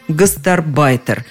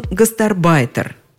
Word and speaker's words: гастарбайтер: Понятно, что оно сместилось ближе к концу слова «гастарбайтер», и все последние словари, и нормативные, и толковые, придерживаются именно такого ударения. Еще раз повторю только, что он гастарбайтер, а они гастарбайтеры